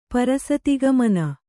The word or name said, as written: ಪರಸತಿಗಮನ